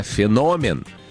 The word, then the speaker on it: феномен: На самом деле у этого слова есть два варианта ударения. Все зависит от значения. Итак, если это слово употребляется в значении что-то необычное, особенное, явление, редкий факт, да, тогда феномен.